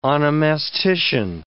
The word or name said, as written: onomastician